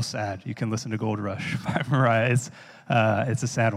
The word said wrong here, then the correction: Mira: So, or Mira, I mean, is how you say her name.